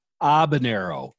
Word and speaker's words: Habanero: It's Habanero Media. – Habanero. I'm sorry. I apologize. – The H is silent. I don't make these rules.